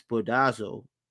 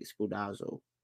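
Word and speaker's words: Spoudazo (σπουδάζω): So do your best in Greek is spoudazo. That's how you pronounce it, I think. Spoudazo. Spoudazo. S-P-O-U-D-A-Z-O.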